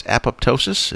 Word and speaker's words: apoptosis: This is, how do you say this? Apoptosis, is that correct? – No, it's apoptosis. – Apoptosis. Oh, okay. Well, I'm glad I asked you then.